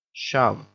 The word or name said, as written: shove